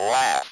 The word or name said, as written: laugh